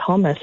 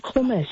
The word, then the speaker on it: hummus: And these days, I think it's pronounced hummus, actually, if you were, you know, so inclined. It's not hummus, it's hummus.